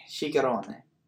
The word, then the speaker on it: Ciccarone: Ciccarone. Confirmed to be the pronunciation.